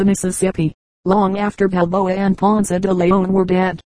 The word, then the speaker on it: Ponce de Leon: Footnote 1, Ponce de Leon, Ponte de Leon, or, in English, Ponce de Leon. Many persons now prefer the English pronunciation of all these Spanish names.